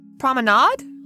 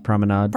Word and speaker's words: promenade: Promenade? – Promenade? Is that how you say it? – Promenade? – Promenade. – Promenade. – Promenade? – Sure. So this is the promenade. – Like lemonade.